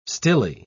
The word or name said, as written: stilly